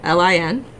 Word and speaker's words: McCaslin: It's Alyssia, and McCaslin, McCaslin.